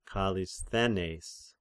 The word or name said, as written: Kallisthenes